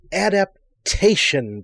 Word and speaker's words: adaptation: Adaptation, Bill. Adaptation. Four syllables, not three.